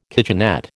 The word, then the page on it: kitchenette: American English